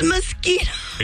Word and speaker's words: mosquito: No, don't say moz. It's mosquito.